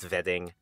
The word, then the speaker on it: Wedding: And that wedding is pronounced Wedding.